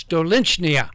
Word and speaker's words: Stolichnaya: And he said, it's Stolichnaya. Stolichnaya. I said, Stolichnaya. I said, that's a beautiful name. What does it mean? It's so clear and so pure that they call Stolichnaya Christ's tear, the tear of Christ.